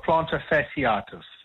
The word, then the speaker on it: plantar fasciitis: Yeah, plantar fasciitis. That's F-A-S-I-I-T-I-S.